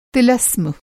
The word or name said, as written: तिलस्म